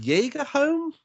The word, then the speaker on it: Jagerholm: Was it Jagerholm? – That's it, in one bang.